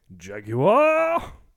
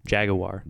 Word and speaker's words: Jaguar: Jaguar! – That's how they pronounce it in England. Jaguar. – Jaguar. – They enunciate the U.